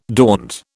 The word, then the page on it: daunt: British English